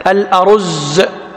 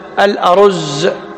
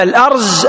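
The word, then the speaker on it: الأُرُزّ: عد هذه معروفة ما يمكن تتخلون عنها لكن الصواب في نطقه بفتح الهمز وضم الراء والزاي المشددة، الأرز، الأرز. أما الأرز، الأرز اسم شجرة معروفة وهي علامة لبنان، وهي التي جاء في الحديث قال: إن مثل المنافق كالأرزة، مو أرزة، أرزة، في فرق.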